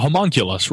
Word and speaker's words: homunculus: Yeah, a human nucleus, or what are they? What's the other type? Human nucleus? How do you say that? – A homunculus, right.